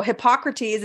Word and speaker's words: Hippocrates: So Hippocrates, is that how you say it? – Yep.